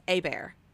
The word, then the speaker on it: Hobert: Like, we have a family friend who's always said their name is A-Bear. So in my head, nice. I've always pronounced it A-B-E-A, yeah. You know, B-E-A-R, or A-B-A-R-E, yeah. You know. No, it's spelled H-O-B-E-R-T. They're from Louisiana. It's covert. – Oh, my God. – Pronounced in their Creole, you know, kind of Cajun.